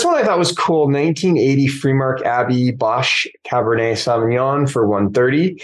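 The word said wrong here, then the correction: Bosché: Boucher. It's pronounced Boucher. – Boucher, whatever. Boucher, sorry.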